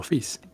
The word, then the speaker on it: Fils: It's like Gaël Monfils instead of my son. It's just son, like Monfils. Yeah. – Yeah. – So Fields, Arthur Fields.